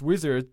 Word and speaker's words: Uizard: And amazing that you specify that it's basically pronounced Uizard, but it's spelled with a U.